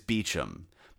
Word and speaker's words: Beauchamp: And while we're at it, Beauchamp is Beecham, Beaulieu Palace is Beaulieu Palace, and Cholmondeley is Chumley.